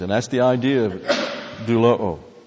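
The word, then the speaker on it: Doulo'o (δουλόω): Doulo'o. A lot of O's in there, but this one's a long O.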